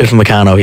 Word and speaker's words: Upamecano: That said, if France have a weakness, it's in, how do you pronounce his name? Upamecano, is it? The centre-back. – Upamecano, yes.